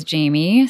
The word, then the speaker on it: Jaime: My name is Jamie, spelled J-A-I-M-E, but in French, there would be an apostrophe after the A, and it means I love. It's pronounced j'aime in French.